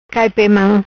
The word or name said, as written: Cai pe mam